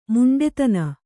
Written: ಮೊಂಡುತನ

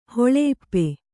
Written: ಹೊಳೆಇಪ್ಪೆ

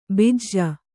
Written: ಬಿಜ

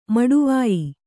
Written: ಮಡವಾಯ್

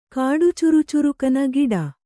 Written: ಕಾಡುಚುರುಚುರುಕನ ಗಿಡ

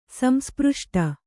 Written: ಸಂಸ್ಪøಷ್ಟ

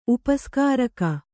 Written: ಉಪಸ್ಕಾರಕ

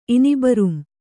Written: ಇನಿಬರುಂ

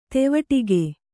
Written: ದೀವಟ್ಟಿಗ

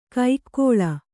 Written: ಕೈಕ್ಕೋಳ

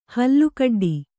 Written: ಹಲ್ಲುಕಡ್ಡಿ

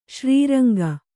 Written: ಶ್ರೀರಂಗ

